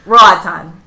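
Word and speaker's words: Roatán: It's Roatan. – Roatan? – Roatan? – Roatan? – I'm going to say in Spanish, Roatan. – Roatan?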